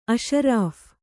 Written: ಅಷರಫಿ಼

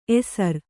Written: ಎಸರು

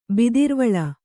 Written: ಬಿದಿರ್ವಳ

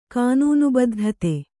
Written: ಕಾನೂನುಬದ್ಧತೆ

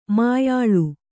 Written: ಮುಯ್ಯಾಳ್

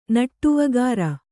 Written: ನಟ್ಟುವಗಾರ

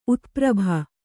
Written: ಉತ್ಪ್ರಭ

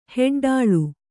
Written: ಹೆಡ್ಡಾಳು

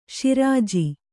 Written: ಶಿರಾಜಿ

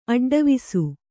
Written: ಅಡ್ಡವಿಸು

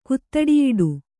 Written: ಕುತ್ತಡಿಯಿಡು

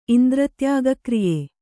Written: ಇಂದ್ರತ್ಯಾಗಕ್ರಿಯೆ

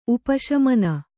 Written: ಉಪಶಮನ

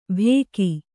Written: ಬೇಕಿ

